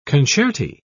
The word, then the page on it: concerti: British English